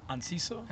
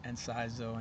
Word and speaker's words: Anciso: Anciso. – Anciso. – Yeah, a lot of people butcher it. Anciso, Anisco, go out a C, a Z.